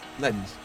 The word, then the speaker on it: Lens: How would you pronounce that? – Lens.